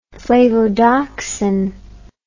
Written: flavodoxin

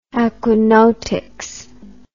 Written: aquanautics

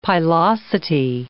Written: pilosity

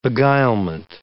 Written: beguilement

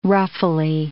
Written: ruffly

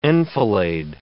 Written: enfilade

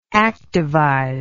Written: activize

